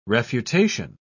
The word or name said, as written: refutation